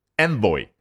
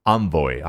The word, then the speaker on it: envoy: 注意这个来使啊，有两种读法，一个就是 envoy。啊，另外一个呢，就是我刚刚念的比较接近于法语的这个读音，叫 envoy 啊。毕竟呢，这个词原本就是法国啊法语当中的。